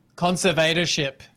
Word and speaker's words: conservatorship: We've been talking a lot about her convertership. I can't even say it. How do you say it? – Conservatorship. – Conservatorship. Thank you very much.